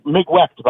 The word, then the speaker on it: Migwecht: Migwecht, by the way, is how you say it, and it means thank you in some American Indian language.